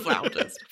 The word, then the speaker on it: flautist: Flautist. Flautist. Thank you very much. – See, look, she even knows the right pronunciation.